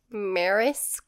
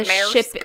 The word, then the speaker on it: Maersk: I don't know if I'm saying that right. It's M-A-E-R-S-K. – Probably it's a Maersk. It's a shipping company. – Say it with an Alabama accent. – Maersk. I'm pretty sure it's like European or something though.